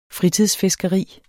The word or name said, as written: fritidsfiskeri